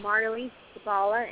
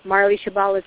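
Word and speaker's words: Marley Shebala: Well, people can just Google my name, Marley Shabala, and the first name is spelled M-A-R-L-E-Y, and last name is Shabala, S-H-E-B, as in boy, A-L-A, and then just Marley Shabala's notebook, and my website will pop up.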